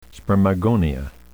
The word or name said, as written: spermagonia